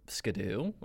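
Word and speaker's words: Ski-Doo: The one I found that I thought was the most interesting, which I've later found out, that Skidoo is you actually pronounce it Skidoo.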